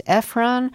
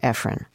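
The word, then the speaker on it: Ephron: Delia, I just feel like I should say here to the people who are thinking, you're mispronouncing her name. It's Efron. And I know that you pronounce it Efron. – That is so correct.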